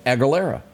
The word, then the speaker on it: Aguilera: Christina, and y'all get this, I'm going to be able to pronounce it, Aguilera.